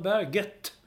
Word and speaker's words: Berget: Varför Jo Inge Berge inte har sagt att han skulle uttalas Jo Inge Berge. Men nu ingen säger det längre. Det var typ två omgångar som man... – Vad hände där? – Jag vet inte. Nu säger man Berget.